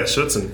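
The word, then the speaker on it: Schutzhund: Am I saying it right? – Yeah, Schutzen. Yes, sir.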